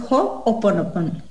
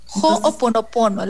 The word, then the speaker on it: Ho'oponopono: En realidad, se dice H-O, apóstrofe, Ho'oponopono. Entonces es Ho'oponopono. – Ho'oponopono, la manera correcta.